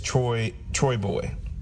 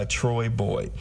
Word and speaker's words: Troyboi: I think it's called Grimy, and the artist is Troy Boy. I think it's spelled T-R-O-Y-B-O-I. So Grimy by Troy Boy.